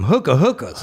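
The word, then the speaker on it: Hoka: Oh, Hokas. – Is that how you say it? – Yeah. – I call them hookahs.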